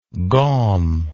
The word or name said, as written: gaumy